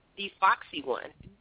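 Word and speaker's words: DFoxxyOne: And that's D-F-O. – How do you say that again? – D-Foxy1. – Mm-hmm. – That's me, and it's D-F-O-X-X-Y-O-N-E.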